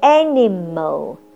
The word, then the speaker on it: animal: Animal, animal 这个字呢只有六个 letters，但发音有三段，高音在第一段 I can draw animals in the zoo.